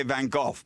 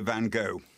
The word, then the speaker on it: Van Gogh: How do you say it, Geoffrey? – Well, I say Van Gogh, but the snobs say Van Gogh.